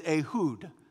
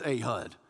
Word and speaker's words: Ehud: Now, probably the best way to pronounce it in Hebrew is Ehud. But here in Alabama, it's just Ehud.